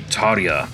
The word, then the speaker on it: Tarja: G'day everyone, and thanks so much for tuning into this chat featuring the one and only Tarja. Yes, sorry, I can't do the rolling R thing that is required when you're pronouncing her name,